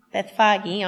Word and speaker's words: Bethphage: As they approached Jerusalem and came to Bethphage on the Mount of Olives,